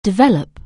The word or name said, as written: develop